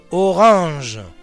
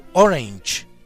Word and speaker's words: Orange: de la familia holandesa de los Orange, u Orange, como se quiera, pronunciada a la francesa, de donde proceden, o a la inglesa, en donde terminarán.